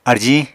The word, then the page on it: arjiñ: Apatani